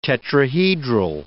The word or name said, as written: tetrahedral